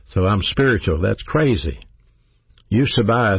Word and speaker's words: eusebeia: That word godliness is a Greek word pronounced eusebia. Eusebia, E-U-S-E-B-E-I-A.